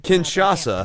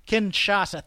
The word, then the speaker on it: Kinshasa: And I was so thankful he did not go through with the Shinkasa or Chinkasa or however you Kinshasa. – Pronounce it. Kinshasa, thank you.